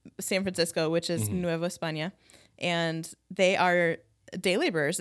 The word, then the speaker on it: España: República de Nueva España. – Yeah. Thank you for saying España, because someone pronounced it Espana or Espana. And I was like, there's no Ñe on that.